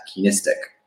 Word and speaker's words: Keenistic: You can find me on Instagram it's Ryan at Keenistic. It's like our last name mixed with heuristic, which is, essentially it's a basically K-E-E-N-I-S-T-I-S-I-S. I can't spell it. – T-I-C.